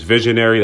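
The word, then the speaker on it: Vis1ary: My name is Visionary. That's spelled V-I-S, the number 1-A-R-Y.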